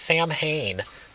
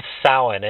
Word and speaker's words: Samhain: Nothing annoys a pagan more than when people say, hey, what are you doing for Samhain? It's Samhain, and it's spelled S-A-M-H-A-I-N.